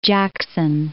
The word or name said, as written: Lake Jackson